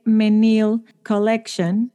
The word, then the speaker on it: Menil Collection: love y está en el Menil Collection, lo dije bien, bien.